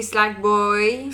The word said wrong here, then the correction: Sackboy: Sack Boy.